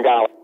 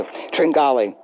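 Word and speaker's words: Tringale: Tringali. – Thank you. Tringali.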